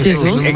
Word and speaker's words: Jesús: Me gusta mucho la pronunciación de Gabriel Jesús. – Pero es Jesús, muy portugués.